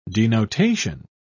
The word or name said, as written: denotation